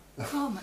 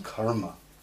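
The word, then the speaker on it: kāma: Kamma, you're talking about kamma? – No, it's kamma. It's K-A, it's a long A-M-A. Which is why, don't get confused with karma.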